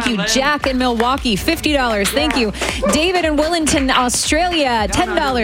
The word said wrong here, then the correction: Wellington: is Wellington. I said it right, didn't I?